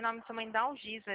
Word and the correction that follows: Alziza: É da Alziza, né? – Isso, Dalziza.